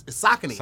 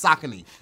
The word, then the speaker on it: Saucony: I'm telling you, it's Saucony. Like, if you look on the website, in parentheses, it says Sock-A-K-N-E-E.